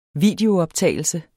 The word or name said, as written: videooptagelse